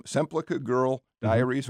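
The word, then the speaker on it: Semplica-Girl Diaries: I go back to a story of yours, Semplica Girl Diaries.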